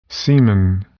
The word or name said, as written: semen